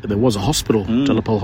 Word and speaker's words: de la Pole: But, the French pronunciation, De La Pole.